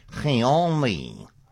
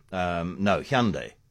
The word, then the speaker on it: Hyundai: Hyundai. That's the South Korean pronunciation, but that's not what you want. Most English-speaking jurisdictions say Hyundai. Hyundai. Most English-speaking direct say Hyundai. Hyundai. The Americans rhyme it with Sunday, so they just say simply Sunday.